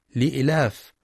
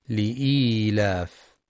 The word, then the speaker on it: لإيلاف: وفي سورة قريش بسم الله الرحمن الرحيم لإيلاف قريش إيلافهم رحلة الشتاء والصيف يخطئ بعضهم فيقرأ لإيلاف بدون نطق الياء لإلاف، والصواب لإيلاف. وكذلك إيلافهم يخطئ بعضهم فيقرأ إيلافهم فلا ينطق الياء الصغيرة بين الهمزة واللام، والصواب نطقها إيلافهم.